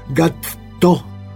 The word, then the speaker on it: Gatto: como mi gato se escribe, pero con doble T, así que me la pronuncia bien, señora, Gatto.